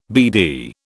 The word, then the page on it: BD: British English